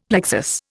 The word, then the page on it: plexus: Indian